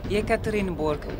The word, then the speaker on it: Yekaterinburg: Yekaterinburg.